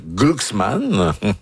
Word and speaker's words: Glucksmann: Et Ségolène Royal a lancé dimanche une première salve contre le candidat Raphaël Glucksmann.